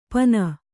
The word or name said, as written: ಪನ